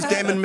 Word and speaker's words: damn: You pronounce the M and the N in damn. – Really? – I heard that. – That was such a camping trip. Didn't we have fun? All those damn mosquitoes, though.